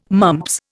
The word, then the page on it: mumps: Indian